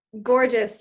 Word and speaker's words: Gorgias: and did I pronounce the name of the company correct for starters? Is that correct? It's Gorgias? – Gorgias. Yes, you hit the nail on the head. Usually people mispronounce it, but that's perfect.